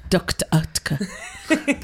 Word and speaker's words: Oetker: pronouncing that right. It's Dr. Oetker. That's how you say it.